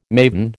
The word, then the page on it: maven: American English